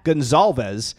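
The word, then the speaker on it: Goncalves: And if you're looking for a Z in his last name, you will not find it, but it's still pronounced Gonsalves. – I'll call him Matt because I'll mess it up. – Just call him Matt. The C is pronounced like a Z.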